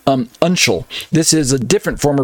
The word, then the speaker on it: uncial: uncial. And this writing, I believe I'm pronouncing it right, uncial, U-N-C-I-A-L, uncial, uncial, I believe it's probably what I should say, uncial.